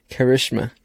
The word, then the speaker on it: Karishma: And today we have a special guest, Karishma.